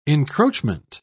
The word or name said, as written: encroachment